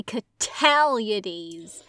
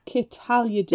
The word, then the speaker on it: Cataliades: These plans are squashed because then Mr. Cataliades. – You gotta say Cataliades. – Cataliades. – Because they even, she even says in the text, stress on the second syllable.